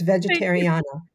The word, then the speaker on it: Vegetariana: It's Vegetariana. As I said, rhyming with America.